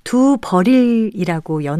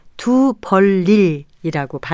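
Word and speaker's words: 두벌일: 특히 두벌 일은 두 벌일이라고 연음해서 발음하지 않고 리를 첨가해서 두 벌일이라고 발음한다는 점에서 유의해야겠습니다.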